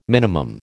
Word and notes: minimum: American English

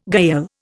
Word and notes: gayal: Indian

